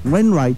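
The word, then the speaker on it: Rufus Wainwright: Oh là là, alors là, attention, vous êtes prêts Il s'agissait de Rufus Wine. Alors, Wayne Ring. Wayne Wright. Wayne Wright. Ah oui, c'est Wright.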